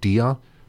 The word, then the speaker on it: Dia: It looks like Diga, but I think it's pronounced Dia. That's from Holding On, which came out last year.